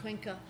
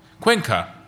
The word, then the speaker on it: Cuenca: She, in fact, has a solo exhibition coming up at the Antonio Perez Foundation in Cuenca in Spain. Is that how you pronounce it? – Cuenca. – Cuenca, that sounds better.